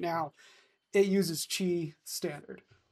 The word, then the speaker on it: Qi: The MagSafe's charging specification, okay, allows for 15 watts of power to be transmitted using the Qi, and that's pronounced Qi from the original Chinese, but written as QI, wireless standard created by the Wireless Power Consortium back in 2008.